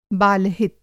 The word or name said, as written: बाल-हित